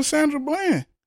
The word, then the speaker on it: Sandra Bland: Where was this shit at for Sandra Bland? – Yeah, for real.